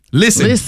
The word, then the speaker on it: listen: Listen is another one where the English words has a silent letter. – Silent and listen? – T. Listen. You ain't no T in listen.